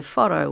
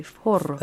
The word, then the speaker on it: forró: And the foro was, have I said it correctly? Foro. – It's pretty good, Foro, And he was, you know, singing in Portuguese.